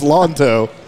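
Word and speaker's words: Lanto: It's pronounced Lawn, L-A-W-N dash T-O-E. It's pronounced Lonto, which cracked me up.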